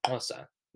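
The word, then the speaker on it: Xhosa: There was some Nordic, Scottish, Welsh, Hossa. No, I think I said it right. Hossa. Yeah, Hossa.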